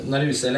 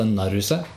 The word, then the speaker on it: Naruse: Egentlig eh, uttales det Naruse? – Naruse. – Okay. Akkurat som Det heter ikke Kurosawa, men Kurosawa.